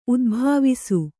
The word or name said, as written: ಉದುಭವಿಸು